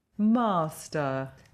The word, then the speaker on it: master: Master. – Master. – Not er, but master. – Master. – No, ah. Master. – Master. – Master. – Master. – It's an ah sound at the end of master.